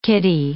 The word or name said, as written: kiddy